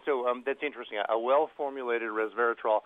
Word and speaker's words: resveratrol: And finally, Restorol. Was it? I mispronounced that probably. Restorol, or the grape, or whatever. – Oh, resveratrol.